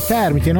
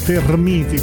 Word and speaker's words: termiti: Il regno delle termiti giganti, una roba del genere, perché si dice poi termiti, non termiti come a volte si dice, quindi Anna Sfera ha fatto centro con l'esatta pronuncia, l'esatto accento più che altro, le termiti.